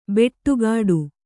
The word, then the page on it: ಬೆಟ್ಟುಗಾಡು: beṭṭugāḍu